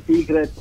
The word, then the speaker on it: Sick Rat: Dicevo, quindi il tuo nome si pronuncia Secret e mi stavi già dicendo anche sì, un po' il perché. – Sì, vuole essere un gioco di parole con, diciamo, segreto, quindi Secret letta così come segreto, però è scritta come Secret, come topo malato, che è un po'...